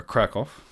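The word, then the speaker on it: Kraków: And he was a seminarian for Krakow.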